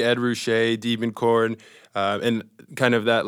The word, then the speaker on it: Ruscha: and L.A. rhymes with, to me, Ruscha sometimes, or it could be a lot of other people.